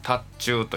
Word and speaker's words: 塔頭: これを禅宗では特に塔頭と読みます。塔の頭と書いて塔頭というんですけども、